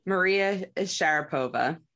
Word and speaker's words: Sharapova: Maria Sharapova.